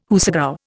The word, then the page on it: hoosegow: Indian